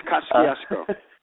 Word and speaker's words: Kosciuszko: I know that when I'm going over the, and I'm going to butcher the pronunciation, and you could probably help me, the Kosciuszko Bridge. – It's Kosciuszko. Kosciuszko Bridge between Brooklyn and Queens on the BQE says it was built in 1939.